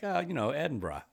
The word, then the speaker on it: Edinburgh: Which Paul Carella told me the correct way to pronounce it is like, he said, you know how you Americans say, hey, brah, what's up, brah? It's that, but with before Edinburgh? – It.